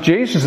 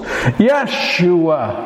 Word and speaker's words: Yeshua: It was a common name in Bible times, that only in Bible times they would have not pronounced it Jesus. They would have pronounced it with two syllables. Yeshua.